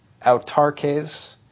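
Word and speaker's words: autarkes (αὐτάρκης): And the word is pronounced autarkes, and it comes from two words.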